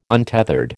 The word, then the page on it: untethered: American English